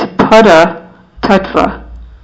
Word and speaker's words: Paratattva: And for the exact pronunciation, it's Pada Tattva and Pada Shakti.